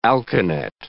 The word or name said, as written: alkanet